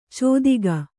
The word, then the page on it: ಚೋದಿಗ: cōdiga; kannada